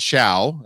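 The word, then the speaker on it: Ciao: It's called Chow, as in food chow, but it's spelled the Italian way, because I think that's sort of the vibe.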